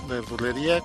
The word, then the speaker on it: Nerdulería: Nerdulería, Twitter, arroba Nerdulería, canal de YouTube, Nerdulería, con acento en la I, Ok.